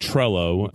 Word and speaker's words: Trello: It's called, we pronounce it Trello, but okay. I know the double L sounds a little different in the Spanish language.